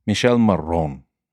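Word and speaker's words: Michele Morrone: Is it Mitchell or Michelle? – I just assumed Mitchell, but I don't know. – Michelle Marone. Marone. – Yeah, that sounds accurate.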